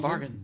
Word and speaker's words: Bargain: Dutch, Dutch Hoe, heet je, hoe spreek je dan? – Bargain. Bargain. Bargain Oh, fuck it.